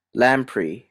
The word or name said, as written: lamprey